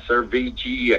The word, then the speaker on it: Servergy: He is a lawyer, and he was representing people as a company called spelled S-E-R-V-E-R-G-Y, Servigy, I guess it's pronounced,